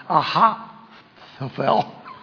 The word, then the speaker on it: Ahithophel: And when Absalom began his revolt against his father David, this individual, whose name is very difficult to pronounce, Ahithophel. You got to spit when you say that if you're Arabic.